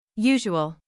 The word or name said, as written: usual